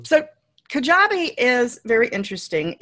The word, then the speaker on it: Kajabi: So we're going to look at some of the fully hosted, and then I apologize about the pronunciation of this, Kajabi. We're looking at Kajabi first. Did I do a reasonable job pronouncing that? – You did. It's Kajabi. – Kajabi.